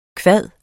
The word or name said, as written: kvad